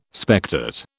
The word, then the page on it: spectate: British English